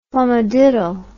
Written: flummadiddle

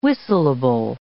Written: whistleable

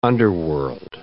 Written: underworld

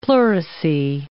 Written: pleurisy

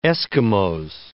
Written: Esquimaux

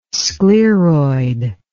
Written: scleroid

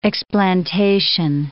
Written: explantation

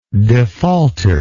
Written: defaulter

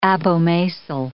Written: abomasal

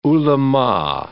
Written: ulama

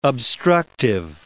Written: obstructive

